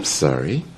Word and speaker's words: sorry: En la palabra sorry, el sonido vocálico O lo vamos a pronunciar como sar. Sorry. Y lo vamos a subir la voz y entonación.